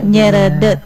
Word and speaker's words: nyeredet: Nyeredet. Gimana Teh bacanya? Nyeredet. – Nyeredet.